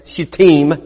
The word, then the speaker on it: Shittim: Early in the morning, Joshua and all the Israelites set out from Shittim, Shittim is the way that's pronounced, and went to Jordan where they camped before crossing over.